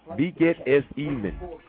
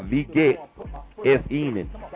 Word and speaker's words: wie geht es Ihnen: W-I-E, and the W is always pronounced V. So it's beget, G-E-H-T, space E-S, space I-H-N-E-N, question mark, which is how are you, formal way of saying how are you. Beget es even. Beget es even.